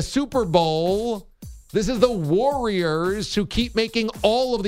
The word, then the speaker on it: Warriors: Why can't we pronounce Warriors? W-A-R-R-I-O-R-S. It's not Warriors. There's no why. It's Warriors.